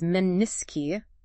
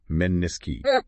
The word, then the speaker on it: menisci: By the way, the correct pronunciation is menisci for the plural. – Meniski.